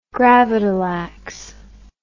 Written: gravadlax

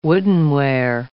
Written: woodenware

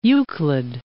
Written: Euclid

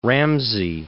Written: Ramsey

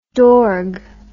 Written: dorg

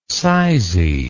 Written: sizy

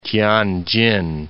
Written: Tianjin

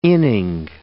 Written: inning

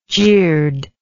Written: jird

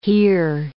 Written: hear

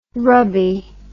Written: rubby

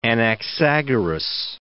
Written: Anaxagoras

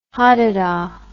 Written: hadedah

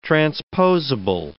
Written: transposable

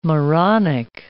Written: moronic

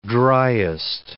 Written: dryest

